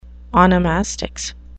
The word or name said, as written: onomastics